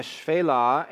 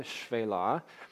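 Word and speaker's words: Shephelah: It says, "Thus Joshua struck all the land, the hill country and the Negev, and the Shvelah and the slopes," that's how you pronounce that word, by the way, the Shvelah, "as well as their kings."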